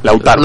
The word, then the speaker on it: Lautaro: no sé si es Latauro o Lautaro. Lautaro. Lautaro. Bueno, este compadre que nos hizo una fiesta ese día.